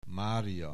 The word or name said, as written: Mária